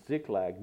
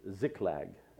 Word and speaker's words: Ziklag: Ziklag. Ziklag is how it's pronounced. That's actually pronounced Ziklag, but, you know, English pronunciation is what we'll go with.